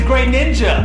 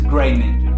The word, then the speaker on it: Greninja: No, it's not. It's Greninja. Google it.